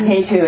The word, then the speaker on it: Hetu: Hetu. – Oh. Hetu. – I don't know the spelling, so I'm probably going to need to... I can blast it off with it. – Yeah, Hetu is root. H-E-T-U. Prabhava. P-R-A-B-H. Long A-V-A. Prabhava. Hetun. H-E-T-U-N. – Hetun. – H-E-T-U-N.